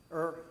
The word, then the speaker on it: Ur: If it's Ur or Ur, does anybody know how it's pronounced? Ur, okay. Located in Mesopotamia.